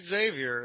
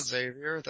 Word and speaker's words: Xavier: definitely it's Xavier. It's not Xavier. If you wanted to spell it that way, then you'd put a Z on the front of it.